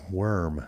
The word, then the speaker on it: Wyrm: And that track was called Worm. And that's spelled W-Y-R-M.